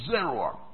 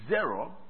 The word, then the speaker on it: zeroah: The arm of the Lord, it is the Hebrew word Zeruah. Zeruah is spelled Z-E-R-O-A-H. Zeruah. Z-E-R-O-A-H.